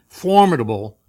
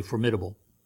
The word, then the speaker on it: formidable: If you're British, you say formidable. But American pronunciation, I looked it up. Formidable. I've just always said formidable, and I said maybe I better check, see if that. So they said two different, and both of them are correct.